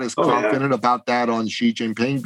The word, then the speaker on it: Xi Jinping: Xi Jinping. Yeah, the XI is pronounced G, not C, but G.